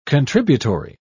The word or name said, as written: contributory